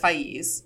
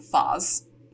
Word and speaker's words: Faiz: The audiobook says Faiz. We found multiple Arabic sources saying Faz. I'm going to go audiobook, but, you know, don't hate me. Faiz and Nadia are getting married, and protagonist Kat and friends Philip and Lynn are in attendance.